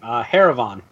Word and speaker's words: Haravan: Haravan. – Haravan. I'm sorry. I keep thinking Haravan for some reason. – Like caravan, but with an H? – Yeah.